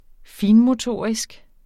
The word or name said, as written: finmotorisk